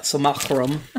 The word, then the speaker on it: simulacrum: Toby's flesh golem, his simulacrum, or whatever you pronounce.